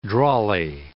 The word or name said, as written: drawly